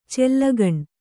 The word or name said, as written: ಚಲ್ಲಗಣ್